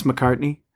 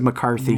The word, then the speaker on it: McCartney: Did you purposely mispronounce McCartney? – Did I mispronounce? – Yeah, you said McCarthy.